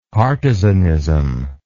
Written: partisanism

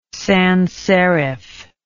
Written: sans serif

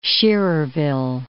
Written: Schererville

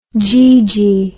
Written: geegee

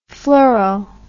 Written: fluoro

